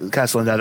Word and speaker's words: Castellaneta: Dan Castellaneta. Did I pronounce that right? – Castellaneta, I believe, is understand.